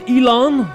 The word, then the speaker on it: Elon: On prononce Elon? – Elon.